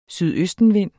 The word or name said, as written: sydøstenvind